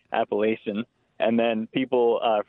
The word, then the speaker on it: Appalachian: You know, the etymology of that comes from a Spanish word, so Appalachian is the correct pronunciation. But there's definitely a regional difference, and, you know, 45% of the trail is in the Northeast, so it's hard to say what the right pronunciation is.